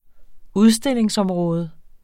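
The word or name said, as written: udstillingsområde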